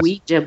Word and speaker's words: Ouija: A Ouija board expert. – Yeah. Yeah, well, that's why I figured I'd tie that. I had that story because I figured it kind of ties into a Ouija.